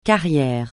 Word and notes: carrière: francais

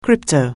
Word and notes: crypto-: anglais

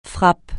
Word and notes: frappe: francais

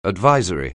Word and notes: advisory: anglais

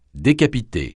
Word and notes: décapiter: French

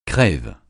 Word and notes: crève: francais

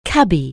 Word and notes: cabby: anglais

